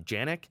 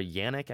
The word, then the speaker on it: Jannik: Um, but one more in, person I was impressed by, um, Jannik, or Yannick, I think you pronounce it, Sinner, um, played S- Stan the Man first round.